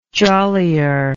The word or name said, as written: jollier